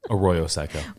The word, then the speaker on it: Arroyo Seco: Arroyo Seco.